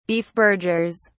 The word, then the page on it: beefburgers: British English